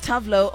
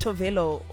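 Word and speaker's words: Tove Lo: It's Tavlo. Oh, apparently it's actually pronounced Tovelo or something like that.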